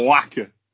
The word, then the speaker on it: Whacker: Whacker. – You've got to pronounce the H.